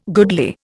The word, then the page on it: goodly: Indian